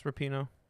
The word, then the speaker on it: Rapinoe: Yeah, is it Rapinoe or Rapinoe? – It's Rapinoe. – Damn, I've been saying it wrong.